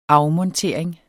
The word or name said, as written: afmontering